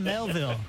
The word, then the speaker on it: Melville: Herman Morville. – Hey, he's close. – You're extremely close. – Wow. – Norville? – No, not Norville. Herman. Herman Melville. – Melville. Oh, one syllable.